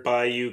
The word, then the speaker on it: bayou: Speaking of water, can you please say the word bayou correctly for me? – You just did, man. You did. – All right. – That's how you say it, bayou.